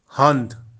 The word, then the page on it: hand: Kashmiri